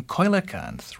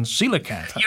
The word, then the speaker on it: coelacanth: So imagine in Greek you'd pronounce your name koilacanth rather than coelacanth. – You're right. In Greek, my name would be pronounced koilacanth.